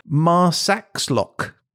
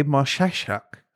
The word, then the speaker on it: Marsaxlokk: Marsaxlokk. Marsaxlokk. – I think the X is a ch sound. – Oh, okay. Marsaxlokk.